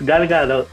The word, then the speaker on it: Gadot: Yo siempre dije Gal Galot, weón. Siempre lo dije mal. – Siempre lo dijiste mal, weón. – Es Gal Gadot.